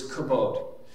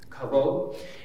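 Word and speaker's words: kabod (כבוד): So I want to introduce you to a Hebrew word today, and the word is kabod. K-A-B-O-D. Sometimes you'll see in the Hebrew, see it spelled with a V, K-A-V-O-D, kabod.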